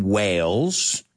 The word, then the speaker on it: Wales: So he's from Wales. Que suena igual que tiburones, ballenas. Pero sin la H se escribe. Pero se pronuncian igual.